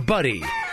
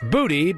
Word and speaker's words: butty: Hot coffee and bacon buddy. Which is basically what we Americans call a bacon sandwich, but they call it a buddy. Spelled B-U-T-T-Y, but not pronounced booty, because that's what Harry and Meghan got after the wedding.